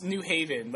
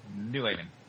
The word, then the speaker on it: New Haven: And I love how they pronounce New Haven. Like, we call it New Haven. Like, it's like one word. It's a New Haven. They're like, New Haven, not like the Old Haven.